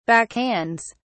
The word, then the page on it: backhands: British English